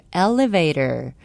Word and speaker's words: elevator: elevator, 승강기, E-L-E-V-A-T-O-R, elevator, 승강기의 정확한 영어 발음은 elevator, elevator입니다. 또 recreation, 휴양, 기분 전환, L로 발음하시는 분들 많으시죠? R입니다. R-E-C-R-E-A-T-I-O-N, recreation입니다.